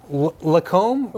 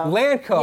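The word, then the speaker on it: Lancôme: Is it Lacombe? – Lancome.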